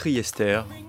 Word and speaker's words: Riester: On dit Franck Riester.